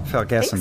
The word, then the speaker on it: Vergessen: Both of you, the Vergessen Sanatorium, am I saying that right? – Vergessen. – I think so, yeah, it sounds right.